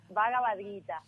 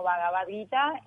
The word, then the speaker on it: Bhagavad Gita: No, Bhagavad Gita.